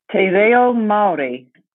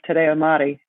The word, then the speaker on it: Te Reo Māori: Okay, how do you pronounce that? – Te Reo Maori. – Te Reo Maori. – Fantastic.